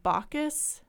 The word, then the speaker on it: Bacchus: Is it pronounced Bacchus? – Yeah.